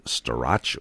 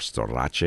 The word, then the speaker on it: Storace: Her name was Nancy Storace, or Storace, if you use Italian pronunciation.